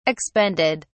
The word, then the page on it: expended: British English